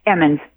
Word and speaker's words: Emmons: Martine, give me your last name because I never get this right. I got your first name. – Emmons. – I said it right the first time.